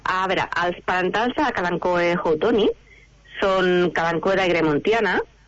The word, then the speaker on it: Kalanchoe: Bueno, jo li dic Kalanchoe, tu has dit Kalanchoe, suposo que en llatí Kalanchoe és més correcte. – Sí, en llatí no es pronuncia l'H, tot i que no es pronuncia bé el llatí, però aquesta me l'he après.